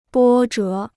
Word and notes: 波折: bō zhé

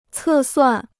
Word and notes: 测算: cè suàn

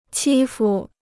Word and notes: 欺负: qī fu

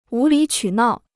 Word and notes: 无理取闹: wú lǐ qǔ nào

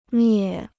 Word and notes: 灭: miè

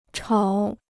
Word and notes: 吵: chǎo

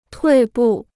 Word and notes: 退步: tuì bù

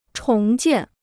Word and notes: 重建: chóng jiàn

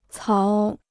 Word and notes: 槽: cáo

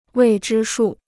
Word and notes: 未知数: wèi zhī shù

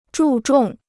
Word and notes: 注重: zhù zhòng

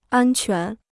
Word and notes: 安全: ān quán

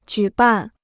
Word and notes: 举办: jǔ bàn

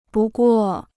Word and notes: 不过: bù guò

